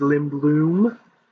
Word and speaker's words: Lindblom: I'm saying his name right. It's Lindblom.